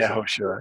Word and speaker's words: Yehoshua: And properly pronounced Yehoshua with the emphasis Yehoshua, on Yehoshua, yes.